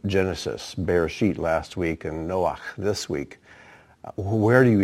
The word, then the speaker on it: Noach: Notice I pronounce it Noach, not Noah, because it ends in a ch sound.